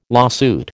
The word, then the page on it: lawsuit: American English